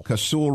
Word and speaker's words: Casull: Casull revolver, the second most powerful.